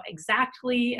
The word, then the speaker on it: Xactly: or maybe you're curious about Exactly, and what Exactly is the name of a company. For those who don't know, it's spelled Xactly, so it's almost spelled like Exactly.